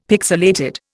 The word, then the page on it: pixilated: Indian